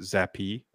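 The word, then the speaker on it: Zappe: A little bit of a zap. – I believe he pronounces it Zappy. – Oh, sorry. French, huh? – Yeah.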